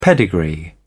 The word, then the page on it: pedigree: British English